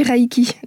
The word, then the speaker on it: Reiki: Reiki, on dit comment On dit Reiki. D'abord, pour respecter la prononciation japonaise de ce mot, EI euh, e, en japonais, ça se prononce EI et pas ai.